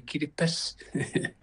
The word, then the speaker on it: Kiribati: Oh, it's just we don't have the S in our language, so we say Kiribati.